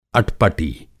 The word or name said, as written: अटपटी